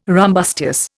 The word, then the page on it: rumbustious: Indian